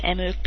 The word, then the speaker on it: mIRC: It says Merc in the MIRC. Jules pronounces it Merc because the I is capitalized.